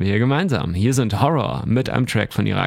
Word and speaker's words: Ho99o9: Apropos glitchy Hip-Hop, wir fahren hier fort mit der Band Horror, nur echt geschrieben mit 9 anstatt vom R, also Ho99O9, ausgesprochen wird es auf jeden Fall Horror.